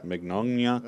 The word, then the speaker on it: Mignogna: Does anyone know how to say that? – Mignogna? – Yeah. – Mignogna. – All right. That works.